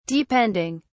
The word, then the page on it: depending: British English